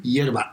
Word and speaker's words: hierba: Yerba. H-I. – Yerba? – H-I-E-R-B-A. So it's like herb with an I inside. – Except the H is silent.